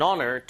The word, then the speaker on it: honor: It is an honor to meet you. 자, 만나 뵙게 돼서 정말 영광이에요. 이럴 때 쓰는 말이죠. 들어볼게요. – It is my honor. It is my honor. – 아시겠지만 이 H는 묵음입니다. It is an honor.